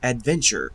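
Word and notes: adventure: Canadian English

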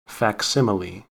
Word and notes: facsimile: American English

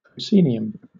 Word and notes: proscenium: British English

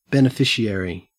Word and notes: beneficiary: Australian English

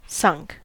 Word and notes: sunk: American English

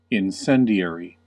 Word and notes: incendiary: American English

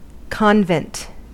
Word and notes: convent: American English

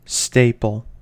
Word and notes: staple: American English

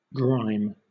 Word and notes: grime: British English

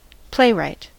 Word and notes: playwright: American English

